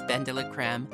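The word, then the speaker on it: BenDeLaCreme: It's Benda La Creme.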